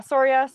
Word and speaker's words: Soria: I'm saying that correctly, right? – Yeah, Soria, either way.